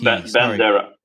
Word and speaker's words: Bandera: Oh, and it's Bandera. That's funny. In the documentary, I think they spell it with a P. – Bandera, yes.